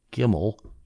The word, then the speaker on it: Gimel: so I'm just assuming this is true the way I'm pronouncing it, if I'm copying her accurately, Gimel, G-I-M-E-L.